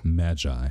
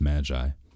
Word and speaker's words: magi: I think, I've always pronounced it as magi. At least that's the way that I've always heard it pronounced, magi.